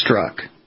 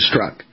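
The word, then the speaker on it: Strzok: He went to Wikipedia and they said it's Struck. So that's the correct pronunciation, Struck. So now that we know for sure, the Z in the middle is just driving people nuts amongst other things.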